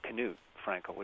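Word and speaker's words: Knut Frænkel: I suppose Knut Frankel would be the proper pronunciation.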